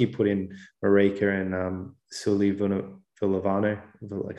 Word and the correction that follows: Suliasi Vunivalu: Suliasi Vunivalu on the other wing.